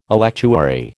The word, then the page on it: electuary: American English